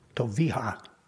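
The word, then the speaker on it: Toviah: Actually, it's a Hebrew name, and it's Toviha.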